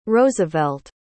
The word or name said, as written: roosevelt